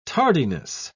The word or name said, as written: tardiness